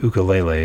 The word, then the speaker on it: ukulele: Also joining us, Jake Shimabukuro, one of the world foremost ukulele players, or as we in the United States, kind of the mainland, call it the ukulele. But the ukulele is the pronunciation that he educated me on. So I will call it that.